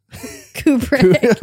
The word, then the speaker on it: Kubrick: Kubrick. – Oh my God. – Kubrick. – Lost all meaning. Kubrick. – Like a dove and then a brick.